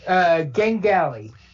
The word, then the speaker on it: Gengali: It's Gengali. Pronounce every letter.